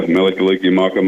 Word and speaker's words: Mele Kalikimaka: Mele Kalikimaka? Did I pronounce that right? – Yeah, Mele Kalikimaka Mai Ohana.